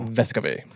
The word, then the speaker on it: Vescovi: I just want to say it properly for you. – Santiago. Vescovi.